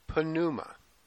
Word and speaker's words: Pneuma: And one of the things I love in Greek, it's hia, it's holy, pneuma, spirit. All right, now, pneumatic drills. Pneuma has that silent P at the beginning. In Greek, we pronounce it.